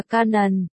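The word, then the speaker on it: Kannon: Quan Âm tiếng Tàu đọc là Quan, Yin, tiếng Nhật đọc là Canon.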